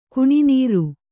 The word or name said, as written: ಕುಣಿನೀರು